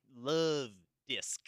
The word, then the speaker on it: Luvdisc: It's spelled L-U-V-D-I-S-C, like Love Disc.